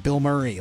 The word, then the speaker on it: Bilmuri: It's spelled B-I-L-M-U-R-I. Bill Murray, like one name.